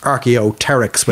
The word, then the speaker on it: Archaeopteryx: Now, the name Archaeopteryx, it comes from two Greek words, which is archaeo, like meaning old or ancient, and pteryx, which begins with a PT. The P is actually silent. So that means a wing or relating to feathers as well. So maybe they actually should be pronounced Archaeopteryx with the P being silent.